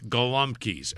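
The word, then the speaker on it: gołąbki: How do you say it properly? – You say it. – Galumpki. – Galumpki. – Galumpki. – Like a W.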